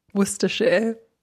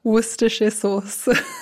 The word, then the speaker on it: Worcestershire: Worcestershire. – Enci una volta. – Worcestershire Sauce.